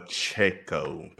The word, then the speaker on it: Pacheco: and I'm probably gonna say his name wrong, but Isaiah Pacheco, whatever the running back is, rookie running back, I believe. Pacheco. – Pacheco. Pacheco.